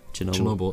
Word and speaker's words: Chernobyl: Chernobyl, that's how you pronounce it, apparently.